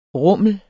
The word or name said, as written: rumlen